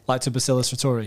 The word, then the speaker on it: Lactobacillus reuteri: And I looked up, and forgive me for how I pronounce this, but lactobius reuteri. – Lactobacillus reuteri.